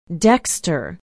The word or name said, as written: dexter